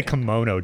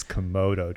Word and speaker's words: Komodo: Why don't you say kimono dragon? Kimono is that thing cool guys wear around the house. – Yeah. – But it's Komodo dragon.